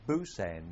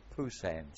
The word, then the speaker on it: Pusan: Funny enough, they call it Busan now, I don't know why, but it was Pusan, spelt P with a P and pronounced with a P.